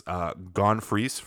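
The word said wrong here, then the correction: Freecss: It's Gon Freaks.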